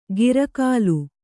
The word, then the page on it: ಗಿರಕಾಲು: gira kālu